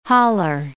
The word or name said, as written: holler